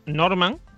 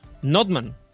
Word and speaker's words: Nordmann: Yo me llevo mucho tiempo pronunciando mal mi apellido. Bueno, pronunciándolo como lo pronuncio normalmente, que es Norman, ¿vale? Ajá. Cuando en realidad en alemán es Nordmann. O sea, la R no se pronuncia.